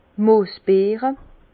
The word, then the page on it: d' Moosbeere: Bas Rhin; Herrlisheim